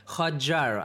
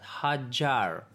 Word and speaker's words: Hotjar: Я не знаю, почему, я не знаю, почему метрика не сделала еще так же, как все у Худжара, понимаешь? Худжара, Hotjar'а. Hotjar, американцы говорят его вот так вот, Hotjar, мне это нравится произношение, значит,